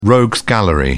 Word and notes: rogues gallery: British English

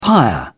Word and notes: pyre: British English

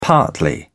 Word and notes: partly: British English

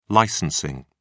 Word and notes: licensing: British English

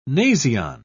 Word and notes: nasion: British English

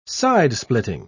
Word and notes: sidesplitting: British English

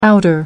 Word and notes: outer: American English

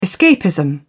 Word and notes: escapism: British English

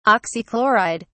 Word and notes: oxychloride: British English

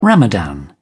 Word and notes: ramadan: British English

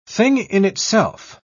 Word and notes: thing-in-itself: British English

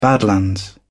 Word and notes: badlands: British English

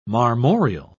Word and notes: marmoreal: British English

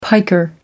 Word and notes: piker: American English

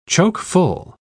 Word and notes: choke-full: British English

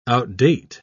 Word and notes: outdate: British English

